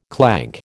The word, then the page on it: clank: American English